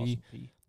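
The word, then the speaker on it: Austin Peay: How do you say it? P? Austin P. – Austin P.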